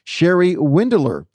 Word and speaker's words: Cheri Windeler: And from Pineapple Gifts, we have the owner, Cheri Windeler.